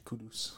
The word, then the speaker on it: Kudus: Kudus? How do you pronounce it? – Mohamed Kudus.